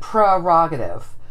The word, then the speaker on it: prerogative: The acceptable pronunciation is what you said, prerogative. But that said, I think 99.9% of the people, and I'm one of them, say prerogative. – I, I do, do too. I don't say prerogative, but that's the correct way.